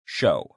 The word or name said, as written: show